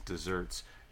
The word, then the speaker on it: deserts: And the modern usage tends to form to get one's deserts, bespelled with one S.